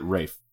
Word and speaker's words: Ralph: My problem with Ray Fiennes is that his name is Ralph. And he pronounces it Rafe.